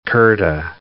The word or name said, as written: kurta